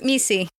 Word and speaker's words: MESE: We'll go back to your, it's called M-E-S-E. – Yep, Messi. – Oh, you pronounce it that way.